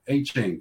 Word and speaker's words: Achane: Achane. – There we go. Proper pronunciation here.